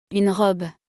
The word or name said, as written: Une robe